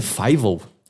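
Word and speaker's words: Fievel: recentemente que a pronúncia é Fivel. Ah, isso eu sabia. Eu não sabia, eu sempre falei FIVO.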